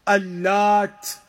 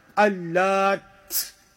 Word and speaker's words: Al-Lat: One of them is when you pronounce the ta without a shadda, Al-Lat. The other one is with a shadda, Al-Lat. One without a shadda, one with a shadda. When you pronounce it without a shadda, Al-Lat, just a ta by itself at the end, no shadda on it, then it is ismu hajarin kabirin amlas alayhi nuqush kanu yatabarrakuna bihi wa yatlubuna minhu qada hajatihim wa tafrij kurbatihim.